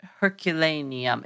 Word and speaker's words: Herculaneum: No, Herculaneum. It's got two extra syllables that need to get cut.